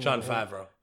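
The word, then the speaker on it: Jon Favreau: John Favreau.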